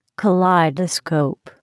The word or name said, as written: kaleidoscope